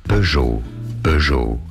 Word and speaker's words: Peugeot: Peugeot, silent T there. In English, Peugeot, from French, Peugeot.